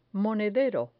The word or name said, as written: monedero